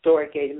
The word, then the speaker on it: storge: So that's covenant friendship, which is filio, and that is spelled P-H-I-L-E-O. And the next love we're going to look at is storge. It's spelled S-T-O-R-G-E in English, but it's pronounced storge in the Greek,